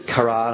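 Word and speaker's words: kara (קרא): The Hebrew verb is kara. I had to get Dr. Davis to make sure I had it, saying it right beforehand.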